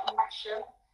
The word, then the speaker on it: Masham: It's spelled Masham, but it's actually Masham.